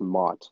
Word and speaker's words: Macht: So my name's Cameron Mott. Last name is M-A-C-H-T. It's German for all you guys that don't know. It's super hard to pronounce, but just know it's pronounced Mott, like M-O-T-T.